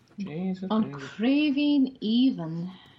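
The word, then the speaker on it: Craoibhín Aoibhinn: So we have Castle Rhee, Lucy Kurtz, and an Irish language term, which I hopefully will do okay with, on Craveen Even. – Craveen Even. – I'll spell it now. C-R-A-O-I-B-H-I-F-A-N. – Craveen. – Creaving. Excuse me. A-O-I-B-H-I-N-N.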